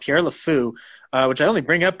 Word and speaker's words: Le Fou: Pierre Le Fou, F-O-U.